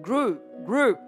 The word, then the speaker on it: group: Mọi người đọc là group ha, mình đọc là group, group, nó là âm u dài, u, group.